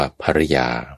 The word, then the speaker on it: ภริยา: คือคำว่าภริยาจะสะกดด้วยพอสำเภารอเรือสระอิยอยักษ์อ่านว่าภริยาก็ได้หรือจะสะกดด้วยพอสำเภารอหันยอยักษ์สระอาอ่านว่าภริยาก็ได้ค